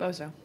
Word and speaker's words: Bozo: how do you say it? Bozy the Clown? Am I saying that right? – Bozo. – Bozo. I wrote down Bozy.